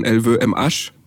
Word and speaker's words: LVMH: Also ich denke da zum Beispiel an den Luxusgüterkonzern LVMH oder vielleicht französisch ausgesprochen LVMH und viele andere.